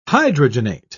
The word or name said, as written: hydrogenate